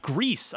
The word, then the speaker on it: Gris: And then Grease, I believe it's G-R-I-S. I think that's how the game is pronounced.